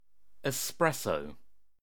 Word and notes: espresso: British English

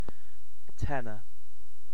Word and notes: tenor: British English

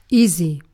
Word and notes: easy: British English

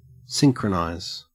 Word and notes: synchronize: Australian English

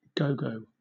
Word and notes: gogo: British English

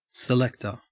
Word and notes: selector: Australian English

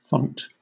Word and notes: font: British English

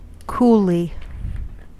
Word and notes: coolly: American English

